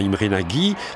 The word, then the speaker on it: Imre Nagy: Le 1er novembre, donc après ces euphorie, confusion, répression, Imre Nagy, je vais prononcer à la française, hein, Imre Nagy, qui est un leader communiste réformateur et qui avait été écarté en 1955 du pouvoir, prend le pouvoir, forme un nouveau gouvernement.